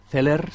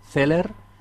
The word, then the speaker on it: Celer: Zeller se escribe tal como lo pronuncio, C-E-L-E-R, Zeller.